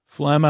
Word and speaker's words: phlegm: P-H-L-E-G-M, phlegm. Silent G's do not only appear at the beginning of words, they can show up in the endings as well. Phlegm, F-L-E-M is how it's said. It uses the letters P-H to make a F-F sound and comes from the Greek phlegm and phone.